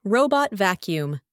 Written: robot vacuum

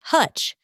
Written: hutch

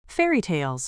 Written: fairytales

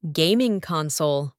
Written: gaming console

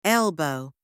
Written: elbow

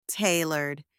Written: tailored

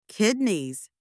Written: kidneys

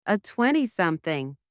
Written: a twenty-something

